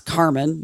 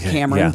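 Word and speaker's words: Carmyn: Then it's C-A-R-M-Y-N. So all they did was flip 2 letters. – Okay. – Which I would pronounce Carmen, not Cameron, but whatever.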